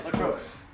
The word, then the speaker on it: LaCroix: Yeah, LaCroix. – LaCroix. – Croix, yeah. There's no X. – They had to spell LaCroix.